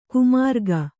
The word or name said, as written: ಕಾಮರಾಗ